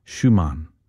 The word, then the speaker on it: Schumann: Bronwyn says there's not enough R in my second syllable of the word Schumann.